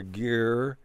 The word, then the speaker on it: gyre: G-Y-R-E, gyre or gear in the Pacific Ocean.